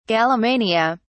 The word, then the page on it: gallomania: British English